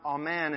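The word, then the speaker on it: amen (אָמֵן): And the word used for believe here is pronounced amen, and it includes the negative.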